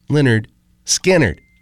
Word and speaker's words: Lynyrd Skynyrd: And we got you Southern Fried Saturday Night, started with a track from Skynyrd's debut album, pronounced Lynyrd Skynyrd.